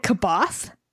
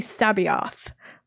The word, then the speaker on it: C'Baoth: which I might have butchered, but it was I had said it Kaboth, uh-huh. which for listeners who don't know Star Wars, it's C'Baoth. Yeah. So it's like it's really Sabaoth. Which took me a long time to correct my pronunciation.